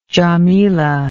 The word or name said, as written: jamila